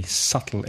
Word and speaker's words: subtle: With a silent B. Subtle. S-U-B-T-L-E.